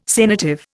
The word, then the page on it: sanative: Indian